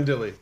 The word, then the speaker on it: Mandilli: Mandilli. – Mandilli. That's easy. Mandilli. – If it ended in an I and people could say it like spaghetti, they'd get it right.